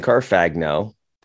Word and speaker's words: Carfagno: Carfagno, just like it's spelled.